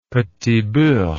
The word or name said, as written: petit beurre